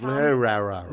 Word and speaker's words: Lehrer: I believe it's pronounced Lehrer.